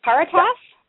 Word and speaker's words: Caritas: Is it Caritas? – That's very good, yes. Actually, the Caritas, but there's no H at the end, so you actually pronounced it perfectly the way it's written, you know, better than most would, so yeah.